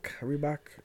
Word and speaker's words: Reebok: Y tenemos que Reebok, Reebok, Rebook... ¿Cómo se pronuncia? – Reebok, eh.